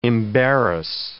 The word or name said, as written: embarrass